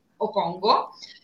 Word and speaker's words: Okongo: su Okongo. Giusto la pronuncia, Francesco? – Esatto, sì, confermo, confermo.